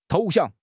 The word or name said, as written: 投降